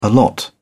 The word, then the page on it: allot: British English